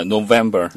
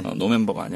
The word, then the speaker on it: November: November. – November. – 잊지 마세요. 네. 노멤버가 아니야. 네.